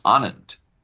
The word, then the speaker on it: Anand: I say my name is Anand, A-N-A-N-D.